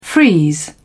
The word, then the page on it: freeze: British English